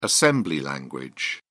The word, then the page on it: assembly language: British English